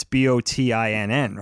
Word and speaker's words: Bottin: It's Botten, apparently. But did you know that it's pronounced Botten? You would have never. – Botten? Oh no, you would never know that by looking at the name. It's Bottin, right? – Bottin.